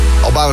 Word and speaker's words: Álvaro: Want in het Spaans spreek je een V uit als een B. En El Mismo Sol bij Q-Music. Álvaro Soler is een Spaanse zanger, zingt ook in zijn eigen taal.